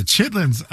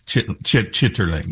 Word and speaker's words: chitterlings: It's spelled chitterlings, right? – Who even determined how it's spelled? – Right. But ain't nobody saying chitterlings.